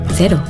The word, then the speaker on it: cero: In New Zealand, zero alcohol Corona, cero, is pronounced cero.